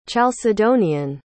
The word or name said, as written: chalcedonian